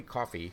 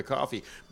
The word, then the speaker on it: Coffey: As Mikey mentioned before, the lyrics for We Go On were written by Don Dorsey and sung by Kelly Coffey, who I still don't know who that is, except for this. – It's pronounced like the drink, but not spelled the same.